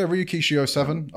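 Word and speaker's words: Ryukishi07: Is it Ryukishi 07? – Yeah, Ryukishi 07.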